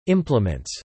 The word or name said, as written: implements